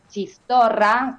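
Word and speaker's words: txistorra: sí, después probé algo nuevo, que es bien típico de la cocina vasca, que se llama chistorra, también se escribe con eh, TS, eh, TX, porque eh, todo lo TX es, che, claro, se che.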